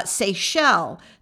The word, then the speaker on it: Seychelles: But anyway, Canada, U.S., United Kingdom, Mexico, Australia, Japan, Finland, Germany, Sweden, Netherlands, New Zealand, Dominican Republic, France, United Arab countries, Seychelles.